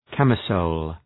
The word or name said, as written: camisole